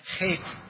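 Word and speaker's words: chet (ח): Now, you might remember that every letter, every verse of this stanza begins with this Hebrew H, this heart H, this pronounced chet.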